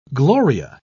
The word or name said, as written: gloria